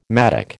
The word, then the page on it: mattock: American English